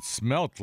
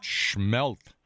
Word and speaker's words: smelt: Yes, you pronounced it smelt like it should be, not schmelt.